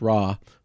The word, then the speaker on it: Ra: So the ninth plague is a big one, the darkness, and it was aimed at the sun god Ra, often looks like R-E, but pronounced Ra, who was symbolized by Pharaoh himself.